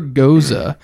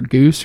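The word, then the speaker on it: Gose: It's a Super Goza, and that's how you pronounce it. I think we've had a Goza. Or we pronounced it Goes before, or Goose, or something, but it's G-O-S-E, Goza.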